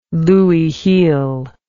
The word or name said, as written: louis heel